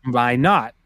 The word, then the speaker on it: Minot: There's a city in North Dakota that has the same name, or at least they're spelled the same, mm. – but it's mm. pronounced by not.